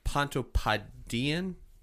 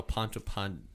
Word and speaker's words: Pontoppidani: So it's A-H-A-L-S. Y-D-R-U-S, then P-O-N-T-O-P-P-I-D-A-N-I. So Pontopodean, because it's probably only pronounced one P.